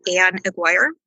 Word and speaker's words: Anne Aguirre: So for me, it was, and I don't know if I'm saying her name correctly, Anne Aguirre.